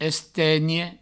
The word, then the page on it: estenhe: Provençal; estegne